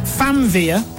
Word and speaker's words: Famvir: It's pronounced Famvir, but it's spelled F-A-M-V-I-R.